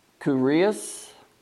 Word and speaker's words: kurios: Just by saying those words, Jesus is Lord, the word Lord here means, and it, in the Greek would be pronounced something like kurios, and it means supreme in authority.